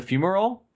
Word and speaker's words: fumarole: It's a fumarole? – Yeah.